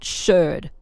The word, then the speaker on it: sherd: That, and I'm not saying that funny, that's not my accent, it's spelled S-H-E-R-D. Whereas if you find a piece of a bottle, a glass bottle, that's known as a shard, S-H-A-R-D.